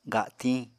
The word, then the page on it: gatiñ: Apatani